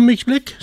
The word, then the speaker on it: Mixblik: Oprichter van Mixblik, zeg ik dat goed? – Zeker, ja.